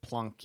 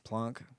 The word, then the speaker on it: Planck: I think the British pronounce it Planck units. – Planck. – Planck. – Yeah. Yeah. I'm going to keep saying Planck, but yeah, you're right.